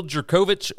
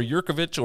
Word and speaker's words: Jurkovec: Phil Jurkovec, or Jirkovic, or however you want to say his name. I believe it's jirkovic Um, he is back.